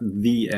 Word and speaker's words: the: Aber wenn du warten oder instand setzen musst, dann ist es Pain in the ass. – Das muss ich rauspiepsen. – Ich habe es doch falsch gesagt. The ass. – The ass, genau. Weil es folgt ein Vokal.